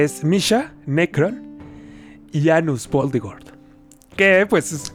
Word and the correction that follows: Anos Voldigord: No es Anus. Dilo como es porque así lo pronuncia él. Anos Voldigord. Anos Voldigord.